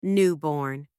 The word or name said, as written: newborn